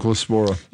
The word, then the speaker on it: Cyclospora: Cyclospora.